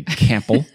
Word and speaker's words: Campbell: Carrie hates the way I say Campbell because I pronounce the P unnecessarily. No one else does.